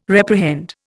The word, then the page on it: reprehend: Indian